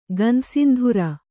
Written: ಗಂಧಸಿಂಧುರ

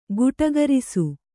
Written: ಗಟುಕರಿಸು

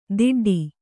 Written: ದಿಡ್ಡಿ

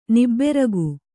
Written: ನಿಬ್ಬೆರಗು

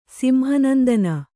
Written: ಸಿಂಹನಂದನ

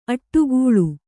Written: ಅಡಿಗಲ್

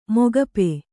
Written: ಮುಕೋಪು

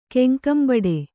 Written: ಕೆಂಕಂಬಡೆ

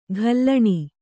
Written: ಘಲ್ಲಣಿ

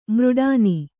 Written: ಮೃಡಾನಿ

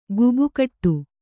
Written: ಗೇಬೆಕಟ್ಟು

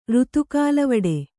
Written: ರುತುಕಾಲವಡೆ

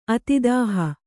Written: ಅತಿದಾಹ

